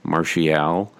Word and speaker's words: Martial: And by the way, Josh, the appropriate pronunciation is Martial.